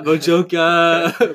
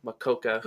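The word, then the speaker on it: Mokoka: Mojita, Mojoka, Makuka. – Totally. – Figured out how to say it. – Just like the animal, the quokka, Adam Makoka.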